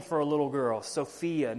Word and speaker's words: Sophia: The word wisdom in Greek is the word Sophia. Literally, that's exactly how you say it. Sophia.